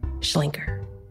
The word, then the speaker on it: Schlenker: If you talk to people in Memphis about Sidney Schlenker, it's very like, Schlenker. You know, it's like this kind of Newman kind of pronunciation.